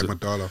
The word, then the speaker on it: amygdala: Which is essentially our amygdala. I can't never pronounce amygdala. – It. Amygdala, yeah.